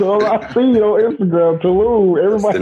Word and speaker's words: Tulum: I'm saying it right, Tulum? – Tulum, yeah.